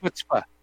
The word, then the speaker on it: chutzpah: It's pronounced chutzpah.